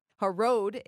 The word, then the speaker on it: Harod: The spring of Harod. Harod is the way it's pronounced.